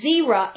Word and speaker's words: Zerah: And it's pronounced, it's Zerah in Numbers, Z-E-R-A-H.